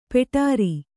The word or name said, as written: ಪುಡಾರಿ